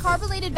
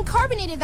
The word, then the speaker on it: carbolated: DL, I heard you mispronounced carbolated Vaseline, and you ended up saying carbonated Vaseline, and swore you were saying it right.